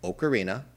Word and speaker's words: ocarina: I hear people say Ocarina all the time, but let me set the record straight for you. It is pronounced Ocarina. And I know some people are like, oh, is a complete blasphemer of Nintendo. No, look, it's an Italian instrument. It's an Italian word.